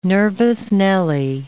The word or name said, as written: nervous Nellie